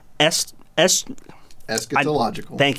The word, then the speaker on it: eschatological: So, this one is what we call an eschatological.